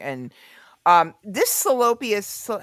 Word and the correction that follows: Theophilus: how you pronounce it? – Theophilus London.